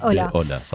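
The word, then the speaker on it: hola: نعم، وممكن نشوف اختلاف في النطق، لأن بعض الحروف تكتب ولا تلفظ، مثلا عندك حرف الهاء صامت ويكتب ولا يلفظ، مثل كلمة الترحيب اللي قلناها أولا، هي تنكتب هولا بس تلفظ أولا.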